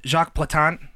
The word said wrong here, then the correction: Jacques Plante: It was Jacques Plante. No one gets points. – Jacques Plante. – Serious? – Jacques Plante is how you say it.